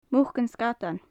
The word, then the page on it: muc an sgadain: Gaelic